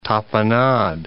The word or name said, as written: tapenade